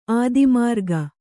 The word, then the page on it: ಅಧೋಮಾರ್ಗ: adhōmārga